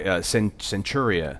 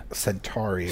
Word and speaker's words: Centauri: Yeah, the great Robert Preston play Centuria. – Centauri.